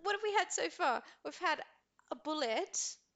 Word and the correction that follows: boulet: A boulet. – A boulet. – That's right, I pronounced it wrong about a dozen times as well.